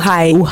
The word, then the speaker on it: uhai: Uhai.